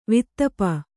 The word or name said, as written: ವಾತಾಪಿ